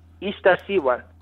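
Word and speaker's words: Iztaccíhuatl: Yeah, so it's Ista Chihuahua. Is that how do you pronounce it? – Ista Siwa. – Ista Siwa? Okay, just the way it's spelled, it just kind of throws me off.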